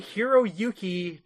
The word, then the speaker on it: Hiroyuki: Kari Hiroyuko. No, sorry. Kerry Hiroyuki Tagawa.